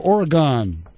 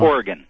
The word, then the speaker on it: Oregon: Is it Oregon or Oregon? – Oregon. – Oregon. Okay. Did I say it right? – Well, one Oregon time is right. Oregon is if you don't live here.